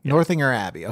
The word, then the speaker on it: Northanger Abbey: Northanger Abbey.